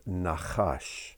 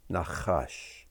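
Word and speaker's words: nachash (נחש): It's the word nachash, nachash. It's spelled nun, chet, shin.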